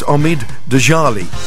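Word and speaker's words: Omid Djalili: The comedian, and I can never pronounce his name right, but I think it's Omid Djalali. That's right. Oh, I got it right.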